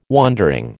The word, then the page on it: wondering: American English